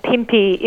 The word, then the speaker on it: Pinpi: I hope I'm pronouncing everything correctly. Pinpi Goes to Paris. It's an exhibition of contemporary Australian Indigenous fashion and design creatives. What can you tell us about that? – Oh, thank you. And Pinpi is how you pronounce it. Well done.